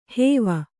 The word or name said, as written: ಹೂವು